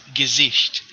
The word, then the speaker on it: Gesicht: If that's face, I think it's Gesicht. – Gesicht? That's probably Gesicht.